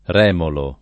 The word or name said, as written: remolo